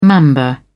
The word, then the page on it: mamba: British English